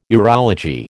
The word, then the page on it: urology: American English